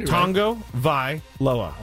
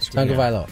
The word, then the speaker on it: Tagovailoa: Tagovailoa. – Okay. – Yeah, I think you got it. – Tagovailoa.